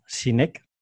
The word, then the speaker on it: Sinek: ¿Nos puedes explicar qué es el círculo dorado de Simon Sinek? Lo he pronunciado bien. – Lo has pronunciado muy bien.